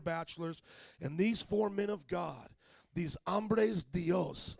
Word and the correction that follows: Hombres de Dios: Hombres de Dios.